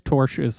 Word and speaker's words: tortious: I looked up how to pronounce that word on Google. It says tortious. I'm going with Google. As well as breach of contract, trademark infringement, tortious interference, and unjust enrichment, and to enjoin plaintiffs from further causing harm to Gaia.